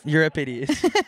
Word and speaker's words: Euripides: That's from Euripides, by the way. – Euripides? – Euripides.